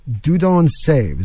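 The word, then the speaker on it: Dieudonné Saive: It was handled by Dudon Save is how you say it in French, a Belgian firearms designer in the employ of Fabrique Nationale.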